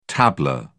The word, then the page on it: tabla: British English